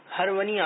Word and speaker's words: ہرونی: ان کے نام کے آخر میں ہرونی آتا ہے, معلوم یہ کرنا ہے کہ اس کا اصل تلفظ ہارونی ہے یا ہرونی؟ – ہرونی.